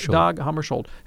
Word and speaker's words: Dag Hammarskjöld: Dag Hammarskjöld, is that how you say his name? – Dag Hammarskjöld,